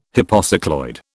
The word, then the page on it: hypocycloid: British English